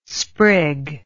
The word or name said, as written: sprig